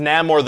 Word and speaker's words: Namor: Yeah, okay, but first of all, it's pronounced Namor the Submariner.